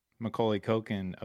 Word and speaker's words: Macaulay Culkin: Yeah, he's like the Home Alone Macaulay Culkin of that universe.